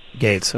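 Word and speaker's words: Gaetz: Gates, I'm sorry. – Oh, yeah, it's Gates. You've been saying it wrong. – Yeah, it's G-A-E-T-Z.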